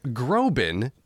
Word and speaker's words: Groban: Josh Groban and Lin-Manuel Miranda, just to name drop a few.